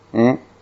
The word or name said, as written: n